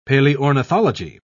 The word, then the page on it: paleornithology: British English